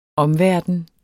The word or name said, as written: omverden